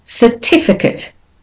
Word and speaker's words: certificate: And the last word, certificate. Certificate. Certificate. So the first syllable, the ER, becomes the weak form, becomes sir, not sir.